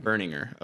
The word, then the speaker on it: Berninger: I think it's Berninger. – Berninger. Okay. – Something like that. Yeah. – It is. – They're my favorite band, so I should know that, but I think it's Berninger.